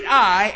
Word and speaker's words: Ai: Now that's, I even looked up a pronunciation. I've been saying it wrong all these years. It's Ai. E-Y-E is the way that city is pronounced.